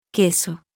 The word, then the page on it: q: Spanish